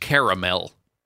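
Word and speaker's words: Carmel: It's pronounced caramel.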